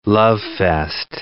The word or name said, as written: lovefest